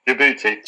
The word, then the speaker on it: Djibouti: I didn't even know how to say the country, forgive me. Djibouti? Djibouti? Djibouti? Djibouti. Djibouti. Apologies to our listeners in Djibouti.